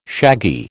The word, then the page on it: shaggy: American English